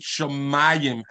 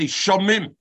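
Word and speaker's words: Shamayim (שמים): How do we pronounce it? Shamayim, as if it had an aleph. Otherwise, we should say Shamim.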